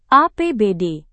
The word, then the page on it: APBD: indonesian